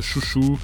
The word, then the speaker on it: Xiu Xiu: C'est Eugene Robinson, bien sûr, qui s'est accoquiné à Jamie Stewart de Chouchou. – Alors, c'est Chouchou, mais en fait, ça s'écrit Xiu Xiu, c'est ça ?